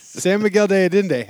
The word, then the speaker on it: San Miguel de Allende: Yeah, hey, has your pronunciation improved of San Miguel de Allende? – Absolutely not. San Miguel de Allende has.